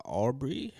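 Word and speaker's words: Arbery: Aubrey.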